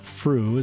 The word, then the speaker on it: Frew: My guests on today's podcast are a married couple, Jessica and Matt Frew. Is that how I say your last name? – That is correct. – That is correct. – So Jessica and Matt Frew, F-R-E-W, are married and in their 30s, and Jessica is divorced.